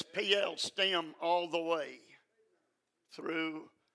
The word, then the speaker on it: Piel: But every time but one, it is expressed in the P-L stem. That's spelled P-I-E-L, the P-L stem.